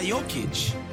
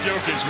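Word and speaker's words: Jokić: Or it could be Nikola Jokic. – Wow. I mean, you said his name right, so it must. And now it is. We're sweeping it. Jokic.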